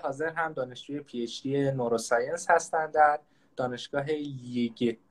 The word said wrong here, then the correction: Liège: اگه اشتباه نتلفظ نکنم، در بلژیک. خیلی خوش اومدید. – لیژ.